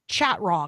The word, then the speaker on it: Chatraw: His last name is pronounced like Chateau with an R in the middle, but it's spelled Chatraw, because I want you to be able to find him.